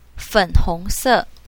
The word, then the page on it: fen3hong2se4: mandarin